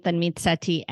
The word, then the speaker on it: Tanmeet: Um, so, um, everything is my full name, Tanmeet MD.